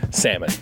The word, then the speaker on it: salmon: Salmon. We don't pronounce the L.